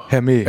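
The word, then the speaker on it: Hermès: Hermes. Oder MS. Also das H ist auf jeden Fall weg. – Das H ist weg.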